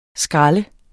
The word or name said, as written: skræl